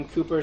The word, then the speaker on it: Cowper: Read about the life of William Cooper. So just Google William Cooper, but it's spelled Cowper,